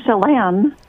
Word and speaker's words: Chelan: Chelan, Washington. Am I saying that right? Listening on KBCS? – Chelan. – Chelan, okay.